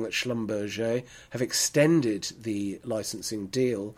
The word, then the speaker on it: Schlumberger: Yes, I said it correctly. Schlumberger, a